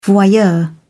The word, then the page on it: voyeur: British English